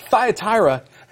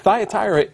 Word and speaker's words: Thyatira: You know, Thyatira is, it would have been funny if I'd have mispronounced it again.